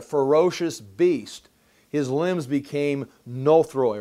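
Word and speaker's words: nothroi (νωθροί): And the word dull, you can look on there, further down there on the page, it's the word nothroi or nuthroi, N-O-T-H-R-O-I is the way you'd spell it in the English.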